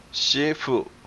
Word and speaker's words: 师父: 师父. – 师父. – Yes, it should actually be an S-H-I.